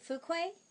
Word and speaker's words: Fuqua: Fuqua, who did a film that I love when you speak French.